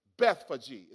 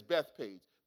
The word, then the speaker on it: Bethphage: Now, when they drew near Jerusalem to Bethphage, Bethphage is actually how it's pronounced. We see it as Bethpage, Bethphage.